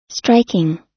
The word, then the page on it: striking: American English